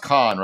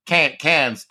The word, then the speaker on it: Cannes: It's pronounced Cannes, right? Cannes Film Festival? – I believe so, yes. – Right. Cans to an American.